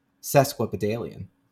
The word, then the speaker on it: sesquipedalian: According to him, I said it correctly. – Oh. – Sesquipedalian. – Good for you. – Correct pronunciation.